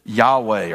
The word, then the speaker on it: Yahweh: We would pronounce it Yahweh or Yahweh in the Hebrew. The old King James said Jehovah.